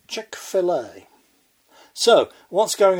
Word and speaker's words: Chick-fil-A: In that state of ignorance, I mispronounced the name as Chick-fil-A. Short A. A mighty host of listeners emailed in to tell me that it's pronounced Chick-fil-A. As in, fillet.